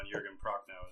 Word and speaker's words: Jürgen Prochnow: And I do believe you nailed the pronunciation on Jürgen Prochnow as well.